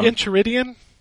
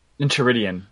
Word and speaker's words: Enchiridion: Okay, so the first game I played, just because I haven't forgotten where it was Adventure Time's Pirates of Enchiridion. I'm not sure how to really pronounce that still. – Oh, yeah, Enchiridion? – Enchiridion, yeah.